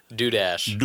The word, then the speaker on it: Dudash: Dudash.